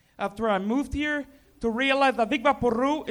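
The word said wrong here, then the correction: Vicks VapoRub: is actually pronounced Vic's Vapor Rub.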